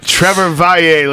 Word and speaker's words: Trevor Valle: Trevor Valle, ladies and gentlemen. That's how you say it if you want to be cool.